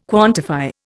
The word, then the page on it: quantify: Indian